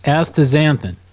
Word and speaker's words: astaxanthin: Astaxanthin, that's how it's basically pronounced, astaxanthin.